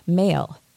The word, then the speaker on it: Mhaol: And I thought, as it was playing, I was looking up, how do I pronounce M bracket H-A-O-L? So this is an Irish word, and the H was put in brackets to let people know that you don't pronounce H, and it's actually male is the way you pronounce that. So M-H-A-O-L, male.